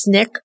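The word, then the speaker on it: Sneek: It's spelled S-N-E-E-K. I believe it's pronounced Snick.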